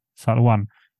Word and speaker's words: Salwan: Salvan heter han va? Salvan, det är, ursäkta uttalet, eh, Salwan.